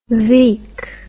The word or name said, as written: zeek